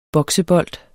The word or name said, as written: BOKSEBOLD